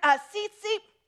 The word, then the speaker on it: tzitzit: The other word that's in the mix of all the word searches is the word tzitzit. It's spelled T-Z-I-T, Z-I-T in the Hebrew.